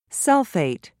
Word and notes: sulfate: British English

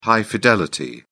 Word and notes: high-fidelity: British English

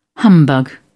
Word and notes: humbug: British English

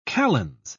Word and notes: kalends: British English

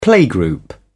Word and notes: playgroup: British English

